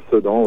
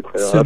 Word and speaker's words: Sedan: Et vous êtes, parce que j'ai fait de mon mieux pour faire de la recherche, vous êtes, le groupe vient de, corrige-moi si je prononce mal le nom, de Sedan, la ville? – Tout à fait, Sedan, oui, Sedan.